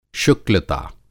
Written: शुक्लता

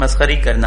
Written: मसख़री - مسخری

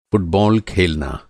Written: फ़ुटबॉल

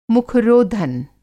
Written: मुख-रोधन

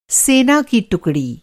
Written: टुकड़ी

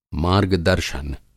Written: मार्गदर्शन

